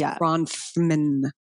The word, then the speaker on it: Bronfman: And I'll tell you why, because you spell her name B-R-O-N-F as in Frank, M-A-N. So it's Bronfman.